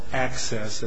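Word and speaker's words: AHCCCS: I'm here on behalf of the Arizona Health Care Cost Containment System Defendants, or ACCESS, as that acronym is pronounced.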